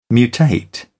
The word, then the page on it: mutate: British English